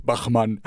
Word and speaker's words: Bachmann: I don't know how you would say it, but in English, in German it's Bachmann.